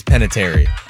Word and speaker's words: Panettiere: Panettiere. Did I say that right? – Karen Filippelli. I think it's Panettiere.